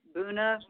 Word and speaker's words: Buna: Not sure how they're pronouncing it. It's spelled B as in boy, U-N-A, Buna, Buna.